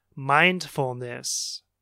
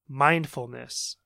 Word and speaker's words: mindfulness: Mindfulness. Do you hear the stress there? The stress should be on the first syllable, mindfulness, mindfulness. So the first syllable, mind, mindfulness.